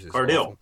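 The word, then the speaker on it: Cardille: The film stars Laurie Cardale. I think that's my pronunciation and names. – Cardale. – Yeah. We'll go with that.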